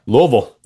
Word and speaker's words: Louisville: Louisville, that's right.